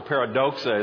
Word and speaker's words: paradoxa: So in the Greek mind, a paradox or paradoxa is how the word would be pronounced.